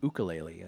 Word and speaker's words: ukulele: A ukulele. – Awesome. – Or an ukulele. – If you will. – Found out that's the correct pronunciation.